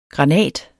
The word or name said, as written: granat